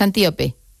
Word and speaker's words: Antíope: ahondas mucho en la relación de Teseo con Antílope, pero me gustaría lo he dicho mal el nombre, es Antíope.